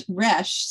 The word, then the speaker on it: Resch: It's Resch. So it's like Resch. Fresh. You just take the F off.